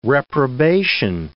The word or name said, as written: reprobation